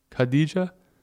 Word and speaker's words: Khadijah: K-H-A-D-I-J-A-H. How do you think that's pronounced? – Wait, spell it again? – I think it's Khadija. Khadija?